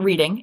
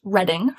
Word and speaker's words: Reading: Not Reading, it's pronounced Reading for those of you outside of New England, which is just north of Boston.